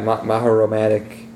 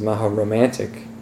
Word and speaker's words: Mahoromatic: Yeah, Mahoromatic, which I often used to mispronounce as Mahoromantic, which would also work, because that would be magic romantic.